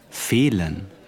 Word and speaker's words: fehlen: Fehlen, F-E-H-L-E-N, which we know is the long vowel.